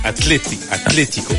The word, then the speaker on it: Atlético: L'accent sur le... C'est Atlético. – Ah oui, non, les accents, j'ai vite abandonné, moi. – Ouais, c'est compliqué, hein, mais voilà. C'est ce qu'on appelle l'accent tonique et c'est sur le... Là, c'est accentué sur la deuxième saison.